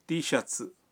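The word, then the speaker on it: تی شرٹس (Tシャツ): اب یہ پوچھ کر دیکھیں کہ کیا ان کے پاس نینجا ٹی شرٹ ہے۔ ٹی شرٹ کا جاپانی تلفظ ہے تی شرٹس۔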